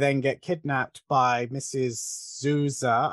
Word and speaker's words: Zuzzer: Yeah, we'll just say how they say, because it's spelled Z-U-Z-Z-E-R, but everybody, even the main actress, says Zuza. And I'm like, okay, it's Zuh. I don't care how it's written. That's how I'm saying it.